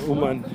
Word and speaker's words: Uman: We were in Ashdod, Herzliya, and obviously Yerushalayim, and planning finally announced the Toronto event for Emunah's Our Future Tour, and with Umen, Uman, Ukraine.